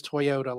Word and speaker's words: Toyota: Her first name is Manami, and I'm sorry if I'm butchering it, but Manami, it's M-A-N-A-M-I. – And then her last name is Toyota, like the car. T-O-Y-O-T-A.